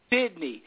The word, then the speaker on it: Cydnee: It's pronounced Sydney. – It's still spelled. – But they spelled stupid. – It. Here's how they spelled it. – Either way you pronounce it. – Yeah. – Yeah. – C-Y-D-N-E-E, pronounced Sydney.